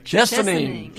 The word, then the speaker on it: Chesaning: It came all the way down here from Chesaning. Is that how you say it? – Chesaning.